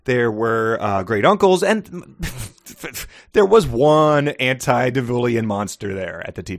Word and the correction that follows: antediluvian: Your inability to pronounce antediluvian troubles me. Try it again, young lady.